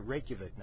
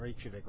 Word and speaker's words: Reykjavik: And yes, I'm pronouncing it Reykjavik now, not Reykjavik like I have been in previous years because I've been convinced that that's not the right way to pronounce it.